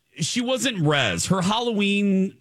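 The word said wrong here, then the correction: rizz: Riz. Riz. Say it right.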